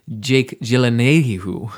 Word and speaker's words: Gyllenhaal: You got Jake Gyllenhaal playing that, or as it's pronounced, Jake Gyllenhaal, which is how he pronounced it.